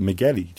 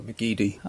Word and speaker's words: McGeady: Some people pronounce it McGeady, too. I never understood what was correct. – No, McGeady.